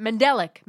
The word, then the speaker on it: mandelic: Mandolin. – Yeah, those are all types of... – Is that how you say it? Mandelic. Mandelic. – Mandelic. That's the Mandelic.